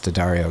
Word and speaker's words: D'Addario: Is like, how do you pronounce that exactly? – It's D'Addario. I watched the thing about them. – That makes sense, D'Addario, because they have the apostrophe.